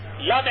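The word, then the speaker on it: la (لا): Okurken la diye okuyoruz. İsmini söylerken lam elif diye söylüyoruz. Okunuşu la, la ilahe, bakın la, la, öyle okunuyor.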